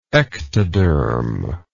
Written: ectodermoidal

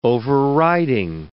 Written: overriding

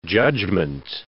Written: judgment

